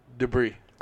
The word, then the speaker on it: debris: You don't pronounce the S? – Don't. – Debris? Is that Mardi Gras? – Don't ever put the S at in the debris.